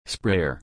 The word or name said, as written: sprayer